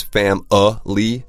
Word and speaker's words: family: 首先它的发音啊 F-A-M-I-L-Y, 但这个 M-I 不发 me, 不是 family. – Right, right. So basically there's two ways you could pronounce this. One is fam-a-ly, family. – Family. – Family. And then the other way is just to say,Family.